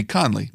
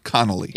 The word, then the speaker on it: Conley: It should be Conley, not Connolly.